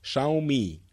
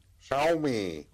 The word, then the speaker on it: Xiaomi: C'est Xiaomi. – Xiaomi.